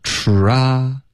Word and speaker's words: try: 需要嘟嘴吃，然后再张开嘴巴 try, 然后再滑到一个 e, try, 所以跟中文的一些发音比如 try, try 的这些发音是不一样的，所以这一定要练好，因为它在口语中是一个高频词 OK, 要练这个嘟嘴的 try,